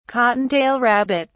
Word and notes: cottontail rabbit: British English

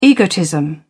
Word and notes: egotism: British English

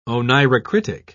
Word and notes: oneirocritic: British English

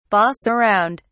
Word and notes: boss around: British English

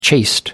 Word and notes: chaste: American English

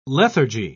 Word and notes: lethargy: British English

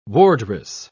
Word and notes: wardress: British English

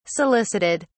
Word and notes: solicited: British English

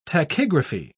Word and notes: tachygraphy: British English

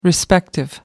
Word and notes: respective: American English

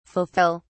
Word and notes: fulfill: British English

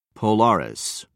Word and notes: polaris: British English

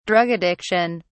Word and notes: drug addiction: British English

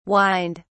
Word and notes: whined: British English

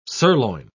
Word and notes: surloin: British English

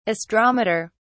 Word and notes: astrometer: British English

